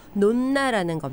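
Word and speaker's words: nonna: 논나라는 겁니다. – 아. – 저는 이게 N-O-N-N-A여서 미국식 발음으로 논안가 이렇게 읽었는데 논나라는 말이 이탈리안 말로 할머니라는 뜻이에요.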